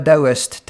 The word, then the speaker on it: Taoist: I went into a Taoist temple, it's spelt T-A-O but said D-O-W, and I watched people coming in.